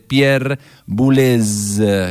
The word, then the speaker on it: Pierre Boulez: Muy buen programa, pero como media francesa que soy, mis amorosos, es Pierre Boulez. Con gracia se pronuncia esa Z.